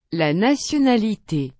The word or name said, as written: la nationalité